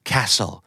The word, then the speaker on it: castle: castle castle สะกดเหมือน castle แต่ออกว่า castle ไม่มีตัวทีนะ